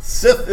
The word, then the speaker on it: Sith: Sith is correct. – Be careful how you pronounce it on the radio. – That's correct. – Yes. – Get that H in there.